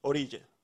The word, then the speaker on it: Orilla: Orilla. – Orilla. – Orilla. – Okay. – Depends on what country you're from. Orisha. – Hey man. Orilla. You know. – Orilla. Yeah. Orilla. – Okay, Orilla, which means what?